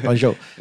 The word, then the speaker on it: Guangzhou: I said that correctly, right? – Yeah, you got it. – Okay, right. Guangzhou.